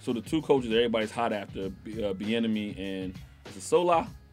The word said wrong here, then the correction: Saleh: Saleh. – Get his name right.